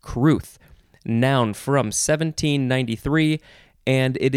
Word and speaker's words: crwth: Next word is pronounced cruth, but it is spelled, these are the best words, C-R-W-T-H.